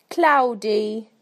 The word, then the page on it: cloudy: British English